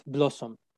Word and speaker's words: Blawesome: So let's talk a little bit about wellness and what was the idea that sparked you to start Blossom. – Absolutely. I've always been... – It's an awesome name, by the way. – Exactly. It's Blossom spelled like awesome. Yeah. B-L and then awesome.